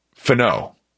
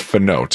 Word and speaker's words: Finau: And I said, second off, he goes, I want you to know that you mispronounced Finau. He said it's Finau, Tony Finau.